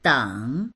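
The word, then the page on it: deng3: Mandarin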